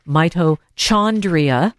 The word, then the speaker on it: mitochondria: So mitochondria, if would be uh, how you would pronounce it, but the H, oh. like it often is, is silent.